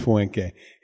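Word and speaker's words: Fünke: Yeah, Tobias Fuenke. He was going to... Oh, you pronounced it correctly, too.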